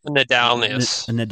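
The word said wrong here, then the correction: Ndalianis: Nadalianus, sorry. – Okay. – Nadalianus.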